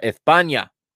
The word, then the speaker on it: España: España. I you like that that Spanish pronunciation there, just like they do in Spain. España. They have uh they've got Unai Simon in goal. Uh, they're playing 4-3-3.